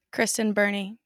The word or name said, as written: Birney